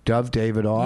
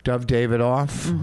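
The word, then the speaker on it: Dov Davidoff: Dove Davidoff. – You know what? You said his name right, and I like that. – Dove Davidoff.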